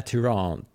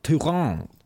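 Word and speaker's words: Tyrande: Men vi har gått igenom det här med Tyrand. Att det är Tyrand. Tyrand. Whisper Wand. Mm. Precis som det är Ishak.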